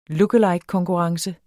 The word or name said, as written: lookalike